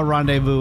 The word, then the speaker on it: rendezvous: R-E-N-D-E-Z-V-O-U-S. R-E-N-D-E-Z-V-O-U-S. – Rendiva? Divu? Rendezvous. Used his brain.